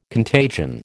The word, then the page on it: contagion: American English